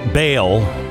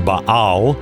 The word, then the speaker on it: Baal: You may hear the word bail. It's actually pronounced Baal.